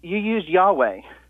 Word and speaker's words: Yahweh: But you use Yahweh. – Mm-hmm. – And that's because of the W pronunciation on the vav. Why is that, sir? – Meaning why do I pronounce it with the W for the vav? – Yeah, I've always heard. I mean, I've heard from yeah because that sources. Yeah that's what originally the vav was pronounced wow, and you still have it like that in Arabic, for example.